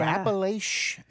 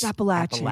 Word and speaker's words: Appalachian: I hate when people go Appalachian. – It's Appalachian. Appalachia, Appalachian, Appalachia, Appalachian. Here's an apple and a latch, Appalachian.